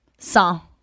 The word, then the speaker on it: sans: Sans? – Sans. – Again, no S. – No S.